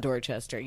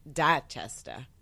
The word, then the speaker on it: Dorchester: And my response to that is it's not Dorchester. You don't pronounce it like that. It's Dachester.